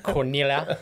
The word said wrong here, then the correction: Cronulla: Just say it. – Cronulla. – Cronulla. – There we go. You got it now, yeah.